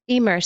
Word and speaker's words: Emer: One of my favorite goddesses was Emer, since we're talking, did I pronounce that right? E-M-E-R? – Yeah.